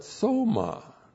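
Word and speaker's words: soma (σῶμα): And then we have not psuche, not soul, but soma. S long O M A, soma.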